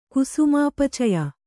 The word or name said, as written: ಕುಸುಮೋಪಚಯ